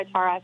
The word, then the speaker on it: Tara: It's Tara. I usually go by Tara, so... – Sorry, Char. – That's okay. Everyone does it. It's cool. It's the American accent. It's an Irish name, so that's the pronunciation of Tara, actually. It's a place in Ireland. So.